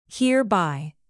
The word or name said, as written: hereby